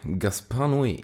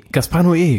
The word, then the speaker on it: Gaspar Noé: Gaspar Noé. – Gaspar Noé, genau.